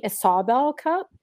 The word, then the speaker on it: Isobel Cup: They keep calling it the mm-hmm. Isobel Cup. It's not spelled I S A B E L L E. It's spelled mm-hmm. I S O B E L. That's the Isobel Cup.